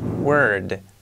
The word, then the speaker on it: word: Enseguida nos vamos a la R bien marcada, word. Hace un efecto casi, no es como una E, pero casi, parece word, word, word, word, word, word, word. Y luego tenemos que hacer la D al final, word, word.